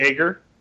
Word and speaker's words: Aegir: Well, I'm from New York, so I say Aegir. – Okay. – But it is pronounced differently depending on where you're from.